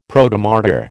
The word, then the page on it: Protomartyr: American English